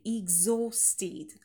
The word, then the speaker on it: exhausted: и exhausted, произносим exhausting на стыке два звонких звука г, з, exo, exhausting, exhausted.